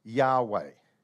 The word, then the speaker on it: Yahweh: The Hebrew letters did not have vowels. You just had to know how to pronounce things. And then later they added little things to give indication about vowels. It's common for older languages to do things like that. And so the best that they understand how to pronounce that is Yahweh, Y-H-W-H, Yahweh.